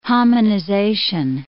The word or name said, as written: hominization